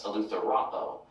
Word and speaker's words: eleutherao: The acute accent mark shows which syllable to stress, eleutherao.